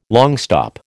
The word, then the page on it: longstop: American English